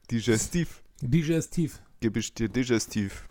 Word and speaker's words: Digestiv: Digestivs. – Genau. – Digestives. Wie ist die richtige ja. – Aussprache? – Digestiv. Digestiv. Digestiv, ja.